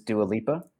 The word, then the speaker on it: Dua Lipa: Yeah, I for the longest time thought maybe I was mispronouncing the name, but it is Dua Lipa.